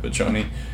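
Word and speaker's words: Vecchione: Mike Vachoni, I think is how you pronounce that. – Vachoni.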